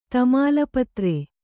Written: ತಮಾಲಪತ್ರ

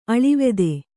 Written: ಅಳಿವೆದೆ

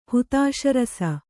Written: ಹುತಾಶರಸ

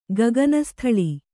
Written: ಗಗನಸ್ಥಳಿ

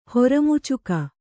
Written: ಹೊರಮುಚ್ಚಗ

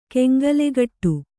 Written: ಕೆಂಗಲೆಗಟ್ಟು

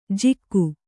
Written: ಜಿಕ್ಕು

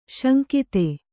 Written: ಸಾಂಗತೆ

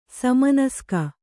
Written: ಸಮನಸ್ಕ

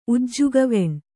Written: ಉಜ್ಜುಗವೆಣ್

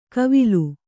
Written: ಕವಿಲು

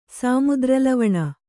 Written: ಸಾಮುದ್ರಲವಣ

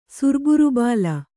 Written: ಸುರ್ಬುರುಬಾಲ

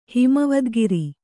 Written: ಹಿಮವದ್ಗಿರಿ

